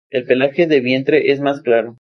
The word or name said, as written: pelaje